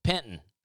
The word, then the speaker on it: Penton: It's important to know that the name is spelled P-E-N-T-O-N, like Penton. But if you say Penton, or if you try to pronounce the T at all,